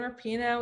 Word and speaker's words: Rapinoe: Megan, I'm going to butcher her name, Rapinoe, the soccer star, right? – Yep, we have Megan Rapinoe, we have Eric Kendricks, Gwyneth Paltrow.